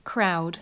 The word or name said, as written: crowd